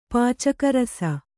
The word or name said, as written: ಪಚಗಾರಿಸು